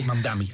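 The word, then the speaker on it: Mamdani: Radical. Mamdami, o nunca pronuncio bien. – Mamdami, sí. – Mamdami. Ajá.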